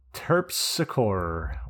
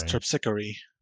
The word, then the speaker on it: Terpsichore: Terpsichore, or Terpsichore, depending on how you pronounce that. – Terpsichore. I think it's Terpsichore.